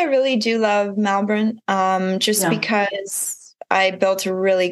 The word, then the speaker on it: Melbourne: Sorry, I should say Melbourne because they don't pronounce the R.